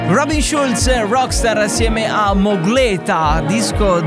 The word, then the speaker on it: Schulz: Questo, come, si pronuncia il, co- eh, come si pronuncia il cognome? Schulz. Robin Schulz, Rockstar Baby.